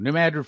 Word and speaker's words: New Madrid: I guess you say that New Madrid. I keep pronouncing it wrong. New Madrid Fault.